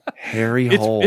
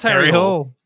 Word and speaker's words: Harry Hole: Is it supposed to be pronounced Hole or something? – Harry Hole. – It's pronounced Harry Hole. It's Harry Hole, and then that is a South Park level name of a detective.